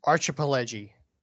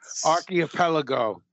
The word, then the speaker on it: archipelago: Archipelagi. – That's not right. – Archipelago.